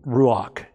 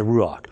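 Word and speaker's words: ruach (רוח): So he's the ruach, is how you say it in Hebrew, the ruach.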